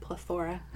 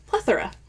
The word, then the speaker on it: plethora: So, you said plethora. – I did say plethora. – Put the emphasis on the wrong syllable on that one. – Did I? – Plethora. – Plethora. I've always said plethora.